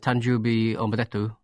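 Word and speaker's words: Tanjubi Omedetto (誕生日おめでとう): I know happy birthday is Tanjubi Omedetto.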